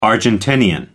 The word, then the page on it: argentinian: American English